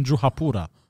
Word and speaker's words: Juhapura: in shrubs near the hotel in Juhapura.